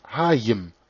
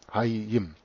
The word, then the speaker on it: Hayyim: Dass Hia bei der Übernahme ins Griechische auch Haya ausgesprochen wurde, wird bewiesen durch das Substantiv Hiim, Leben, Hayim ausgesprochen, also H-A-Y-Y-I-M, Hayim.